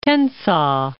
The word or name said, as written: Tensaw